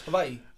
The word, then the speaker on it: Hawaii: It's how it's pronounced, Hawaii.